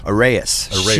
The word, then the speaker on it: Arreis: How do you say A-R-R-E-I-S. – Arrayus.